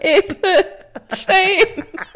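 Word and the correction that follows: Ethel Cain: Ethel Kane.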